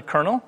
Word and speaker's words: colonel: In the French army, this rank wears five gold stripes and is actually said as it's spelled. – What is a colonel? – Colonel.